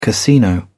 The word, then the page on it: casino: British English